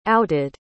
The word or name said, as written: outed